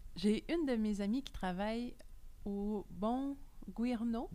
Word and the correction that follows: Buongiorno: Buongiorno. Buongiorno. C'est l'italien.